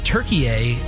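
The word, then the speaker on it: Türkiye: Yeah, it's Turkey, but everybody's pronouncing it Turkey. All the announcers, I think that's really? – The proper pronunciation for the country, so I decided to go with that pronunciation.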